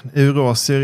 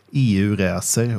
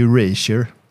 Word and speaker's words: Eurasier: Så därför kan man ju tänka på landmassan Eurasien när man uttalar namnet. Smart. Ja, men vi har ju även hört många andra uttal som till exempel EU-racer och eh, Eurasier med flera.